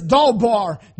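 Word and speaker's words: dawbar (דבר): It means, the word is dawbar. It's pronounced like D-A-W-B-A-R. That's not the way it's spelled, but it's pronounced dawbar.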